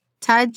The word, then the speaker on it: tej: I don't know if people are drinking that here, like Tej and Tella. Am I saying that right? – Yeah. So Tej is honey wine.